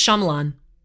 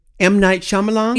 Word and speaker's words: Shyamalan: Because do you know how to say his name? – I think it's Shyamalan. – M. Night Shyamalan? – Yeah.